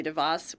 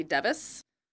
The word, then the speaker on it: DeVos: Sometimes they say Betsy DeVos, which is how you pronounce her name. Sometimes they say Betsy DeVos, which is not correct.